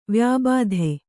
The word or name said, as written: ವ್ಯಾಬಾಧೆ